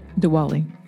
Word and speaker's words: Diwali: And just like that, this episode is number 6, Diwali. Am I pronouncing that right? – Diwali. – Diwali. Diwali. Yes.